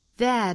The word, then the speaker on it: there: there 这个词我们注意 th 要咬舌，之后呢发成一个 air air 的双元音，这是英音读法。